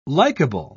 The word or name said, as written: likable